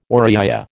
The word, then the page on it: Oriya: American English